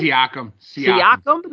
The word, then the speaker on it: Siakam: I know they got Siakam? Is that how you say his name? – Siakam.